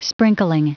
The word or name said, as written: sprinkling